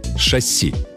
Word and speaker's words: шасси: Шасси. Ударение в этом слове только на последний слог. На «и», а не на «а», как любят говорить многие, даже, казалось бы, вполне грамотные люди.